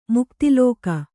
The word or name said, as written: ಮುಕ್ತಿಲೋಕ